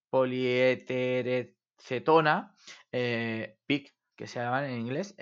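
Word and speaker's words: PEEK: Estos PEC, que se escriben, o PIC.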